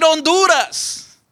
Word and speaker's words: Honduras: Es como decir Honduras. Lleva H, pero nunca pronunciamos la H.